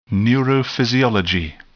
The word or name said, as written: neurophysiology